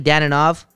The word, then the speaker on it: Dadonov: Evgeny Dadonov from Florida.